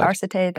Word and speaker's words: Arcite: and it's Palamon and Arcite. Mm-hmm. Is that how you pronounce it? Arcite? – Arcite.